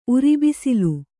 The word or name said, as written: ಉರಿಬಿಸಿಲು